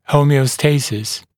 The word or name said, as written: homeostasis